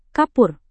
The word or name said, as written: kapur